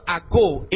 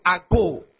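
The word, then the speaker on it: ago (ἄγω): Actually, the word to be led, as many as are led, in the Greek, is the Greek word ago, A-G-O. A-G-O, ago. It actually means, not ago, I know what I'm pronouncing, don't think, I know what I'm saying. A-G-O, ago. It actually is not English, okay?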